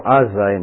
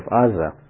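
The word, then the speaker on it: Azza (עזה): or Azza in Hebrew, you know, because Western languages cannot pronounce those guttural sounds, so So gradually it became Gaza instead of Aza.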